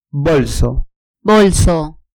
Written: bolso

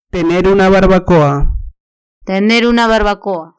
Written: tener una barbacoa

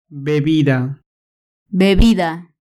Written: bebida

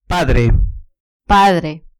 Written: padre

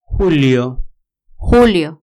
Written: julio

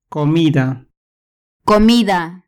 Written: comida

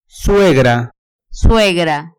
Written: suegra

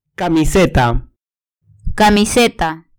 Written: camiseta

